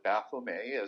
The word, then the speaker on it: Baphomet: And if you look at who the Templars were worshiping in secret, that would be Baphomet or Baphomet, as I would pronounce it, because it's only a single T and there's no E on the end.